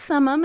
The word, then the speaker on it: Summum: Is it Summum? Is that how I say it? – It's pronounced Summum, as far as I understand it.